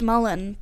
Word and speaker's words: mullein: Anyway, apparently it's pronounced mullen, but... – That's okay. Drink your John Mullein tea. – I read the things. I don't talk to people, so... – Look, it shouldn't be pronounced mullen. Let's take a second here. Look at the way that word is spelled. There's no reason it would be pronounced mullein.